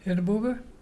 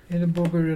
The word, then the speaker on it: Irrabuga: Edinburgh. Edinburgh and dolphin. You spell it I-R-R-A-B-U-G-A.